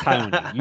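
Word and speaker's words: Raoni: You did a hard R. Come on. It's Haoni. You know that. – Yeah, it's Haoni.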